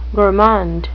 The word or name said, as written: gourmand